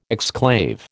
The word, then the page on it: exclave: American English